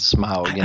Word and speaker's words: Smaug: It is indeed Smaug, I often find myself going back and forth too.